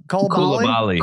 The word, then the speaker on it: Koulibaly: Bilal Koulibaly? – Koulibaly.